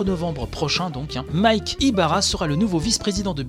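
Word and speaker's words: Ybarra: Euh, bref, deux semaines après avoir annoncé son départ, l'ancien corporate vice-président de Xbox, Mike Yabara, on en avait parlé la semaine dernière, je crois que j'ai dit Yabara tout à l'heure, ou Ibara, bref, excusez-moi.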